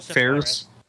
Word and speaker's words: Fares: Joseph Fares, or however you say his name. Fares. Yeah. Yeah.